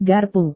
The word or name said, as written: garpu